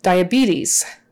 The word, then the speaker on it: diabetes: Got the long E in the middle there, diabetes. So the IA is pronounced separately, IA, IA. it's not IA, it's not IA, it's IA, like a short U, ah. I don't know why. Dia, dia, diabetes. So those other two E's are long, diabetes, with a Z on the end, not an S.